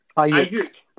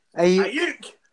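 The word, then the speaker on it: Aiyuk: Ayuk. Brandon Ayuk. Yeah. – Ayuk. – Come on, you should be able to say it in your Scottish accent. Ayuk.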